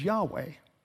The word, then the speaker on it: Yahweh: Our English equivalent is YHWH, and our best shot at pronunciation is Yahweh.